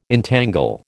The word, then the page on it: entangle: American English